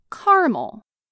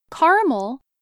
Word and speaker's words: caramel: Our final pronunciation variation is a difference in how many syllables make up the word caramel. Syllables are the parts a word is naturally divided into when pronounced. Caramel, 2 syllables, or caramel, 3 syllables, is a light brown candy made from butter, sugar, and milk.